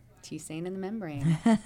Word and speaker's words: tisane: How do you pronounce the word T-I-S-A-N-E? – T-Sane. – T-Sane. Okay, good.